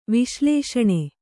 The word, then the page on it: ವಿಶ್ಲೇಷಣ: viślēṣaṇa; Kannada